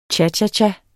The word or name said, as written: cha-cha-cha